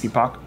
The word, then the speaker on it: Epoch: Or epic. Yeah, I pronounced it epic, but then someone else was like, I think it's epoch. – Well, the actual word's epoch, but they probably pronounce it epic.